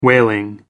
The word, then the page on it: whaling: American English